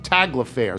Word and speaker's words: Tagliaferri: From Rebecca Snyder, please wish a happy 33rd birthday to Wes Tagliaferri. From Rochester, New York, and the last name is pronounced Taglafair, so I got that wrong.